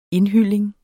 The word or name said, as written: indhylling